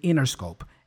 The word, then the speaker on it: Interscope: Usually, especially in American English, the T is dropped. So we just say Interscope. And that's how she pronounces it.